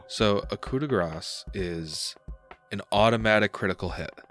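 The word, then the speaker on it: coup de grâce: So as a coup de grace, that is, hold on, am I pronouncing that right, or is it coup de grace? – I think it's supposed to be coup de grace.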